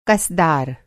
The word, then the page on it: कस-दार: कसदार